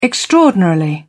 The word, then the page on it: extraordinarily: British English